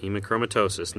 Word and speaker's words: hemochromatosis: Hemochromatosis.